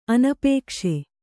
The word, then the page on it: ಅನಪೇಕ್ಷೆ: Kannada